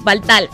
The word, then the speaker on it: Valhalla: Él es del grupo 86 de la provincia Valle Noreste del grupo eh, a ver si, si, si lo dije bien, Baltaya, ¿cómo es? Valhaya. ¿Sí? Ajá, Valhalla. Ok, muy bien. Valhalla, como, como los vikingos.